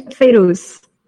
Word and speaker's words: Fairouz: Fairouz, am I pronounced that correctly? Just want to check. – Yeah, Fairouz. – Fairouz, thank you very much.